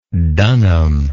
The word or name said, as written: dunham